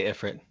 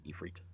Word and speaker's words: Ifrit: Or is it Ifrit? I say Ifrit. – It's Ifrit when it's the I, but I think sometimes it's spelled E-F-R-E-E-T. So okay. That would be Ifrit.